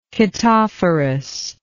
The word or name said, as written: chaetophorous